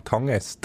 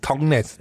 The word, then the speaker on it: Tangnes: ich sage ihn falsch, der Dan Tangnes. Tang, Tang, Tangnes. Ich musste husten, vielleicht ist das das. Nein, du hast richtig gesagt, Dan Tangnes. Tangnes.